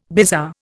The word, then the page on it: bizarre: Indian